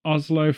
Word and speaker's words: Oslo: It's like someone said, why is that guy, that crazy guy, in Norway, Oslo, right? I think that's right. I think I said that right. – Oslo for the people who aren't Vikings.